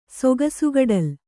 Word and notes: ಸೊಗಸುಗಡಲ್: kannada

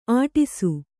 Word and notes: ಅಟ್ಟಸ: Kannada

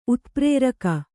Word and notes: ಉತ್ಪ್ರೇರಕ: utprēraka

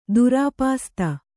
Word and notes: ದುರಾಪಾಸ್ತ: Kannada

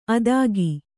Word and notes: ಆದೀಕ: Kannada

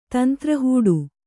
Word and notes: ತಂತ್ರಹೂಡು: Kannada

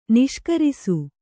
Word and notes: ನಿಷ್ಕರಿಸು: kannada

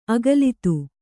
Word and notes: ಅಗಲಿತ್ತು: agalittu

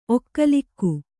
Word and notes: ಒಕ್ಕಲಾಗು: okkalāgu

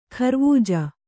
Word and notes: ಖರ್ವೂಜ: kharvūja

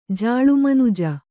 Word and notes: ಜಾಳುಮನುಜ: kannada

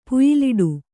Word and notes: ಪುಯ್ಯಲಿಡು: puyyaliḍu

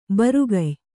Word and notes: ಬರುಗಯ್: barugay

